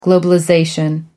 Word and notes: globalization: American English

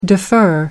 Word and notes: defer: American English

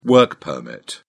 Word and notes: work permit: British English

